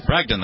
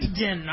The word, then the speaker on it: Bragdon: Did I say that right? Bragdon, that's right. – Bragdon, all right. – Get those hard GD syllables, yes.